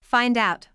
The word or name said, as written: find out